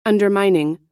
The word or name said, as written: undermining